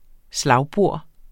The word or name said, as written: slagbor